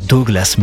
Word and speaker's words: Douglas: Ça s'écrit Douglas, mais lui il dit ça comme ça, Douglas,